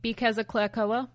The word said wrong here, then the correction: cloaca: Or maybe I pronounced it wrong, but... – Cloaca? – Cloaca, that's what it is.